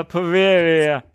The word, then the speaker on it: Poveglia: Sprich mal das so aus, ja, Poveglia. – Ein Italiener hat so ausgesprochen.